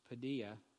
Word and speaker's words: paideia: And so the Greek word, I think you say it's pronounced paideia, P-A-I-D-E-I-A, occurs five other places as chastising or chastisement, I should say, chastening and instruction.